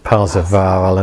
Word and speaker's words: Parsifal: So it's Percival in German. They pronounce it Parsifal, and that's where we get Wagner's opera Parsifal from.